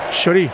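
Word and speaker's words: Chorý: Chori. Chori. Acento en ahí entonces. – Con Y al final.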